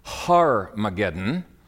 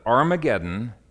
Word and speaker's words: Armageddon: Some try to make this Har-mageddon, but John is quite explicit in his pronunciation. He says that Armageddon is the Hebrew pronunciation.